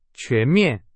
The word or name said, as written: 全面